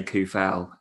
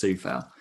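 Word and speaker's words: Coufal: And then we used to say Koufal and everyone else did, but now it's Soufal.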